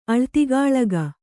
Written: ಅಳ್ತಿಗಾಳಗ

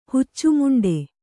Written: ಹುಚ್ಚುಮುಂಡೆ

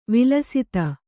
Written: ವಿಲಸಿತ

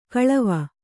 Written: ಕಾಲಿವೆ

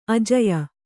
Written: ಆಜ್ಯ

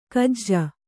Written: ಗೂಜು

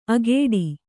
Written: ಅಗೇಡಿ

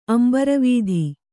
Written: ಅಂಬರವೀಧಿ

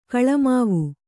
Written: ಗುಲಿಮಾವು

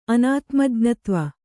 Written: ಅನಾತ್ಮಜ್ಞತ್ವ

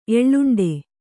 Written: ಎಳ್ಳುಂಡೆ

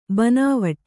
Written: ಬನಾವಟಿ

